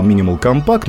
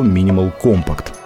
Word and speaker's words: Minimal Compact: В свое время я ее называл Minimal Compact, но все-таки если соблюдать правильное ударение, то Minimal Compact.